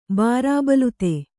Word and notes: ಬಾರಾಬಲೂತಿ: bārābalūti

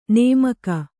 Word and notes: ನಮಕ: kannada; namaka

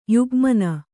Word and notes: ಯುಗ್ಮನ: Kannada

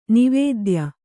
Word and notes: ನವೋದಯ: navōdaya

